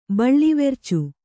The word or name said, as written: ಬಳ್ಳಿವೆರ್ಚು